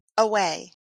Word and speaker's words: Aoaeh: And how does he pronounce his last name? – Away. – Away. – Like far away. – He doesn't spell it that way. How does he spell it? – A-O-A-E-H.